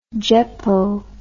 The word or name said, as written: jeppo